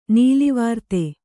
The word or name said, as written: ನೀಲಿವಾರ್ತೆ